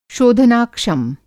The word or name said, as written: शोधनाक्षम